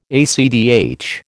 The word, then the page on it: ACTH: American English